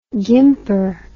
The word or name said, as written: gimper